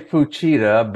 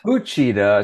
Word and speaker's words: Fuchida: I say Fuchida, but Fuchida. it's Fuchida. It's like it's, it, in Japanese, they don't use accents on, on many words. So it's just Fuchida Fuchida, but I have a hard time with it myself. So Fuchida is fine, but that's how it's pronounced.